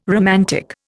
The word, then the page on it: romantic: Indian